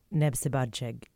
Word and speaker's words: Népszabadság: Tell me a little bit about Nebsibadzeg. Did I pronounce that correctly? – Yes, you did.